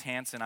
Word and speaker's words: Hansen: My last name's Hansen.